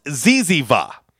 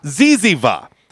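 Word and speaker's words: zyzzyva: It's Z-Y-Z-Z-Y-V-A. – Okay. – It's pronounced Ziziva. – Ah. – Ziziva.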